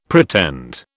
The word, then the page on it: pretend: British English